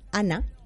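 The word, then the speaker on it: Għana: Se escribe Gana con GH, pero se pronuncia Ana, ¿vale?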